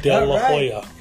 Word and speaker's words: De La Hoya: that's how you say it. – De La Hoya.